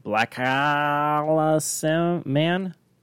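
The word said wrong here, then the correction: BlacKkKlansman: Black Klansman. – Oh, Black Klansman.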